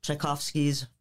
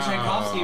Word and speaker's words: Tchaikovsky: She's performing Tchaikovsky's Violin Concerto. – Wow, you said Tchaikovsky right.